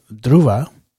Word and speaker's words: Dhruva: It's called Dhruva, and it's spelled D-H-R-U-V-A, Dhruva. D as in dog, H as in Harry, R as in Romeo, U as in urban, V as in Victor, and A as in Adam.